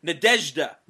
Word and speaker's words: Nadezhda: If you remember some past weeks of news, they had lost Nadzia Tanakovia. I'm probably totally pronouncing her name wrong again. – Chris Barrett explained it to us. Nadezhda.